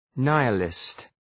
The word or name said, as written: nihilist